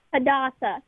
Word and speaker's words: Hadassah: Hadassa. – Hadassa? – Hadassa? – Mm-hmm. – How do you spell that? – Yeah. – How do you spell that? – H-A-D-A-S-S-A-H.